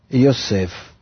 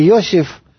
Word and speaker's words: Йосеф: Вот этот уровень, он называется Йосеф. Я произношу это на иврите, не Йосиф или как-то еще, потому что мы потом будем изучать, что называется это слово Йосеф.